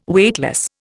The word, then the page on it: Weightless: Indian